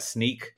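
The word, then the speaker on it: Snyk: SYNK. How do you pronounce that? Sneak. – Sneak.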